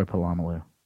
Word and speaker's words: Polamalu: Troy Polamalu. I could have fixed it. – Let's go with Polamalu.